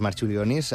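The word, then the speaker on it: Marčiulionis: Jo crec que li diuen Marciulionis, però... Perquè aquesta C es pronuncia com una TX.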